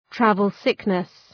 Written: travel sickness

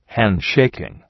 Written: handshaking

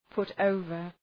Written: put over

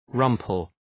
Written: rumple

